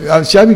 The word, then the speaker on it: Xavi: Claro, Xavi que sería como Javi.